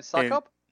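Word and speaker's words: Succop: So for me, like my top five are Koo, Zuerlein, Bucker, Scoop. I never pronounced that right. Gold. – Ryan Suckup. – Suckup, thank you.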